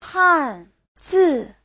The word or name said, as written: han4zi4